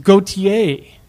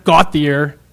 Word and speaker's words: Gauthier: G-A-U-T-H-I-E-R, she admonished me, is pronounced Gautier, not Gautier.